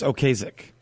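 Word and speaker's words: Ocasek: I think it's Okusek. – Okusek, okay.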